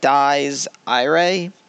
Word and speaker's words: Dies Irae: So it's literally spelled D I E S space I R A E. So it's like Dies Irae, but it's German, so I assume it's like Dies or something.